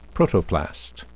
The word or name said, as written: protoplast